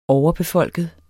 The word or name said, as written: overbefolket